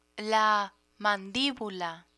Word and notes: mandibula: spanish

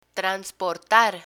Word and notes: transportar: spanish

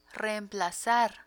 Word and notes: reemplazar: Spanish

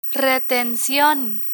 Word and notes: retencion: spanish

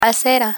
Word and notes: acera: Spanish

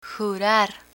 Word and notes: jurar: Spanish

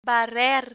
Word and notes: barrer: spanish

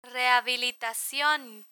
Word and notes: rehabilitacion: Spanish

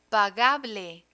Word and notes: pagable: Spanish

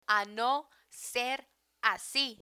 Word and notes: a no ser asi: Spanish

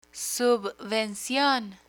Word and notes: subvencion: Spanish

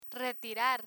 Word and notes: retirar: spanish

